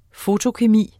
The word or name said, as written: fotokemi